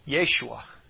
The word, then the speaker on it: Yeshua: Because Joshua and Jesus are both spelt the exact same way in Hebrew and pronounced the same way. Yeshua.